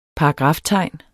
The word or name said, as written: paragraftegn